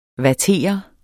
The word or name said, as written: vattere